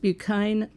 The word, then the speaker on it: Buchan: But the Buchan Street is now pronounced Buchane Avenue by the people who live there. Sounds better.